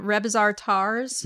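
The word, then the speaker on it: Rebazar Tarzs: This one is actually attributed to Paul Twitchell, but he goes on a walk with, how do you say this again? Rebizar Tars? – Yeah, you got it.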